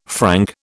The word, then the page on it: franc: British English